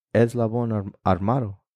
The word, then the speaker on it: Eslabón Armado: Eslabón Armando, or Armado. Damn, it's not even Armando. It's Eslabón Armado.